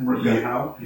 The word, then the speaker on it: Rutger Hauer: Would you say that's how you pronounce his name, Rutger Hauer? – Yeah.